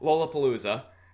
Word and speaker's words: Lollapalooza: He's done Lollapalooza. I mispronounced that so bad. – That was fascinating. I've never heard anybody say it like that. – Lollapalooza. Lollapalooza. – Say Lola. Wow. – Lollapalooza. There we go.